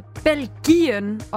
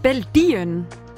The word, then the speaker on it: Belgien: Thomas skriver, at det hedder Belgien, og ikke Belgien, som mange udtaler det. – Belgien. Belgien. Ja, det er også fuldstændig rigtigt. – Altså med G eller med D.